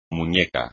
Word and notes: muñeco: espagnol

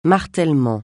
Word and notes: martèlement: francais

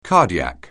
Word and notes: cardiac: anglais

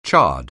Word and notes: chard: anglais

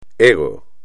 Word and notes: ego: espagnol

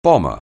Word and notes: bomber: anglais

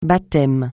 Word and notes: baptême: francais

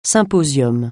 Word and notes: symposium: francais

